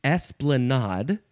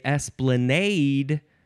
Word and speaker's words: esplanade: The next word is esplanade. Or S, you can emphasize the first syllable or the last syllable, or you can say esplanade and emphasize either syllable.